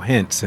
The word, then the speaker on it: Hintz: I'm your host, and today, folks, I have a great guest, and his name is Daniel Hintz.